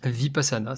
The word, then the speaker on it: Vipassana: que Jérém est parti faire une Vipassana, si je le prononce bien. Jérém, tu me dis Vipassana, c'est exactement voilà, La Vipassana.